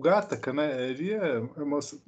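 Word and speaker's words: Gattaca: Gente, seguinte, é porque o Gataca, o Gataca, aliás, você que é o nosso consultor de cinéfilo, daí qual, qual é a pronúncia certa aí? Gataca.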